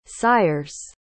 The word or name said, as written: sires